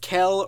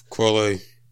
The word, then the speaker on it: Quelle: But yeah, that's Skinless, so now let's move on once again to the dear old duo of Jean Grey and I'm still not sure if it's pronounced Kel or Quelle Chris. – Quelle.